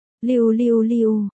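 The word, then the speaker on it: liu liu liu (六六六): Trong tiếng Trung cụm số 666 được viết là liu liu liu, và cách phát âm của từ này là liu liu liu, cách đọc của nó có phần tương đồng với chữ niu phát âm là niu, tức là trâu hoặc bò.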